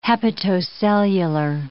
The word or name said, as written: hepatocellular